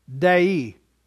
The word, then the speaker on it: dei: The Greek word that is used there is pronounced dei. It's like the word day with a short I on the end of it.